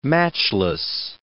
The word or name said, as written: matchless